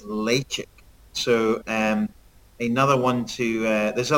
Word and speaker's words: Ledaig: So it's spelled L-E-D-A-I-G. Now, most people would think that's Ledaig. But it's actually pronounced Lechick. – Yes, Lechick. – Lechick or Lechick.